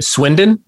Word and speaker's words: Swindon: We want to start from the beginning when you were a wee lad in Swindon. Am I saying that correctly? – Yeah, very good.